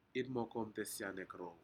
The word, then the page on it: inmokotesianmekrou: Aneityum